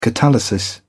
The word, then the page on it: catalysis: British English